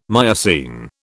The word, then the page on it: Miocene: British English